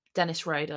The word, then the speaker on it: Dennis Rader: Denise Rader? – Dennis Rader.